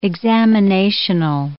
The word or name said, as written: examinational